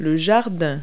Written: backyard

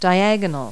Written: diagonal

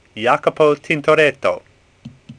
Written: Tintoret

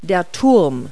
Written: tower

